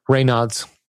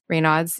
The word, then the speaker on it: Raynaud's: Raynaud's. – Raynaud's.